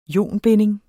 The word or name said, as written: ionbinding